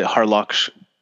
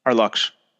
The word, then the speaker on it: Harluxsh: I pronounce it Harluxsh. – Oh, you pronounce it Harluxsh. – Harluxsh.